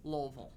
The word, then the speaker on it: Louisville: To say it correctly, you got to say it with a bunch of cotton in your mouth or crap in your mouth. – Louisville.